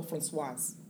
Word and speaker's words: François: Is it François or Françoise? – Oh, François.